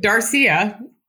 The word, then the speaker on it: Darcia: I gotta go with Darcia, rhymes with Sangria,